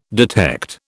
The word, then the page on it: detect: British English